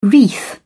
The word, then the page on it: wreath: British English